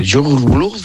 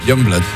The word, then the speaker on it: Youngblood: Es que era muy difícil de pronunciar, pero luego ¿cómo se pronuncia? – Youngblood.